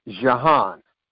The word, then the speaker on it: Jehan: My name is Jahan, that's spelled J-E-H-A-N, last name Mansoor, spelled M-A. – Hold on. The first name, J-E-H-A-N. – Pronounced Jahan.